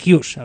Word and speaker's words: Hughes: Pero bueno, el grupo es lo formó él, como decimos, junto a Jess Hoss, que también este sí que estaba en la formación, mejor dicho, Jess Hughes, a ver si pronuncio bien, que mi inglés es horroroso.